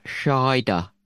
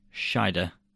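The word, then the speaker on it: Scheider: Schneider. How'd you say his name? Well, I don't know. There's no N in it, is there? Schneider. – Oh, did I give an N in it? Okay. Schneider.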